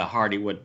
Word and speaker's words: Hardywood: No, I've gone kind of light tonight, but I'm on the Hardywood Pappy's Pumpkin Pie. – No. – O'clock. Hello. – How do you say Hardywood? – I believe it's Hardy Wood.